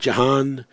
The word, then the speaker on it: Gihon: And the name of the second river is Gihon. Some people pronounce it Jahan.